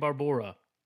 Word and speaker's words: Barbora: First name, Barbora. – Barbora. – Maybe it's Barbara, but it's just with an O, so...